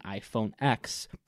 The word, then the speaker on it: iPhone X: Apple announces the iPhone 8 and iPhone X, pronounced 10.